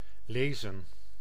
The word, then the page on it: leggere: [ˈlɛd.dʒe.re]